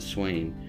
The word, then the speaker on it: Samhain: So without further ado, the tradition of Halloween originated with the ancient Celtic festival of, it's spelled Samhain, like S-A-M-H-A-I-N, but it's actually pronounced several different ways, and I think the American pronunciation is Swain.